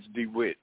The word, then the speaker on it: DeWitt: My name is DeWitt. – Can you spell that, please? – D-E-W-I-T-T. – DeWitt. D-E-W-I-T.